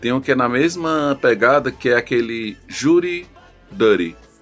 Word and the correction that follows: Jury Duty: É Jury Duty, né? A, é, pronúncia Jury Dirty,